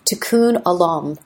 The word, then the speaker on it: tikkun olam: And as they said, the Consul General from San Francisco said last week, and I hope I'm pronouncing it correctly, tikkun olam.